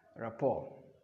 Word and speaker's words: rapport: Rapport. – But the T is silent. – Rapport.